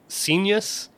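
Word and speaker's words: Scenius: and is it pronounced Senius? As in, yes, I knew you were going to ask about Senius.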